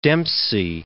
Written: Dempsey

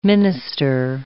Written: minister

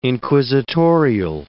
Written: inquisitorial

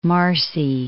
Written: Marcy, Mount

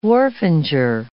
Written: wharfinger

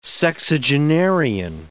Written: sexagenarian

